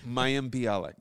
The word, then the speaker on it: Mayim Bialik: Wait, say her name again. – Is it Mylene? – Say it. Commit to it. – I can't. We're going to edit that part out anyway. – It's Mayim Bialik.